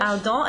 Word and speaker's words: Ardent: Ardent. A-R-D-E-N-T. That E-N-T becomes an A nasal.